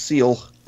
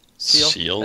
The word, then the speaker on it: Seal: Ari says that his character's name is pronounced Seal. – Seal? S-E-A-L. – I thought it was like Sale.